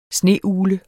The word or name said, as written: sneugle